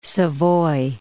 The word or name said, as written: Savoy